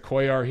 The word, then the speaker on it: Cuellar: And I know everyone pronounces it Cuellar here, but the guys from Argentina and everyone I've ever talked to from Argentina says the L's is Cuellar.